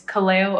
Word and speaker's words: Kaleo: Kaleo, I hope I've pronounced that correctly. It's a wonderful name and Hawaiian in origin, is that right? – Yes, and you pronounced it great. That was awesome.